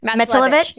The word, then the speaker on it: Matulewicz: Matelovich.